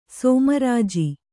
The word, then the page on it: ಸೋಮರಾಜಿ: sōma rāji